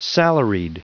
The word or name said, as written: salaried